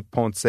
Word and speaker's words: Ponce: I'm at Anthony Ponce TV. Last name is spelled P-O-N-C-E.